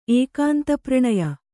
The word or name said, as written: ಏಕಾಂತಪ್ರಣಯ